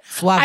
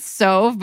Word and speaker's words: Sauve: how do you say it? Suave? – I think it's Sauve because it's S-A-U.